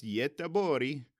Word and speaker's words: Göteborg: But the Swedish name is apparently pronounced Göteborg.